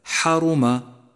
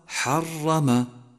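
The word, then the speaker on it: حرّم: رابعا، احذر من اللحن الجلي عند قراءتك كلمة حرم فتخفف المشدد، أي هكذا حرم والصواب حرم.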